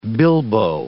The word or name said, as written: bilboa